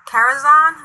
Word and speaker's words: Karazhan: He's up there raiding with in Karazhan. Am I saying that right? – Yep, it's one of our raid leaders.